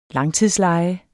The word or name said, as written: langtidsleje